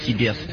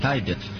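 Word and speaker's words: Tibet: Этот альбом спродюсировал известный музыкант и философ, и поэт Дэвид Тибет. Точнее, ему правильно говорить Дэвид Тайбет. Ну, мне удобнее и приятнее говорить Дэвид Тибет.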